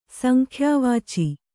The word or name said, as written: ಸಂಖ್ಯಾವಾಚಿ